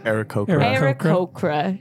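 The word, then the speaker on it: Aarakocra: Akrokra? How do you say it? – Arakokra, a bird man.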